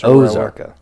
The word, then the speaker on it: Ozarka: I think it's Ozarka.